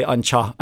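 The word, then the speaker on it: an teach: Some people pronounce it chach or tea, but I would say an cha, and that means the house.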